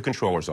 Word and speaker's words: comptroller: I am going to use what's in the New York City Charter, which is comptroller. – Eliot Spitzer also flirted with the comptroller pronunciation when he jumped into the race last month, but now he appears to be firmly in the controller camp.